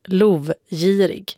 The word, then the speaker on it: lovgirig: Vi har fått många mejl av era lyssnare, och det handlar om ordet lovgirig, som uttalas just så och inte på något annat sätt.